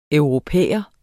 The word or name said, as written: europæer